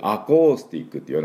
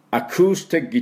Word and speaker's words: アコースティック: だからちょっとあって出すだけ、大きくアコースティックって言わなくていいです。アクースティックというふうに出します。で私の後について発音していきましょう。アクースティックギター、アクースティックギター。